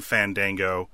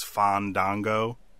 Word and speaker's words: Fandango: I think when he originally debuted as a bad guy dancer character, the announcer would call him Fandango, and he would interrupt the announcer and tell him that he was pronouncing it wrong, that it is Fandango.